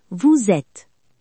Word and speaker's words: vous êtes: Y recuerda la unión sonora o liaison como en vous êtes.